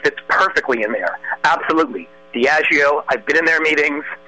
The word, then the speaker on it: Diageo: Another point, one quick thing, is it's pronounced Diageo, by the way.